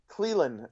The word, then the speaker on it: Clelin Ferrell: But then Mike Mayock and John Gruden with their first draft pick take, is it Cleland or Clelin Ferrell? – Cleland. Cleland Ferrell is the way he likes to pronounce it.